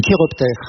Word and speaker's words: chiroptère: Les chiroptères. – L'ordre des chiroptères, ça s'écrit CHI. Et ça se prononce bien chiroptère.